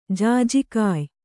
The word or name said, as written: ಜಾಜಿಕಾಯ್